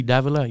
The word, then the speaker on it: Davila: Davila.